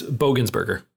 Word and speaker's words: Bogensberger: It said part of, how do you pronounce his last name? Is it Bogensberger? – It's Bogensberger.